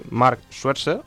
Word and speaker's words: Mark Schwarzer: Y ha habido otros movimientos en el mercado invernal, como el tercer portero del Chelsea, Mark Schwarzer.